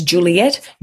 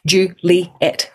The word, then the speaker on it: Juliette: So my name is Juliette, Juliette, right? There's three syllables.